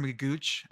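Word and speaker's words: McGough: I started calling him Mr. Magooch. I think that's funnier. It's pronounced Magoo. It's M-C-G-O-U-G-H.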